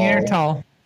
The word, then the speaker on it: Neanderthal: Neanderthal. Ah, Neanderthal. – Neanderthal. Neanderthal. – Yeah, Neanderthal featured. – H is silent.